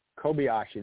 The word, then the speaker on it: Kobayashi: Make sure I pronounce this right. Kamui Kobayashi. – Kobayashi, there we go.